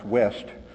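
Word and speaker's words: Wuest: From Worst, or actually pronounced West,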